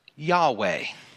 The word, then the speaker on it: Yahweh: Another word that you find for God is one that we pronounced Yahweh. Yahweh. Y-A-W-A-H.